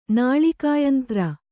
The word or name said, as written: ನಾಳಿಕಾಯಂತ್ರ